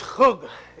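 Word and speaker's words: chug (חוג): But the Hebrew word is chug. If you were to transliterate it, would be K H U G. It's chug.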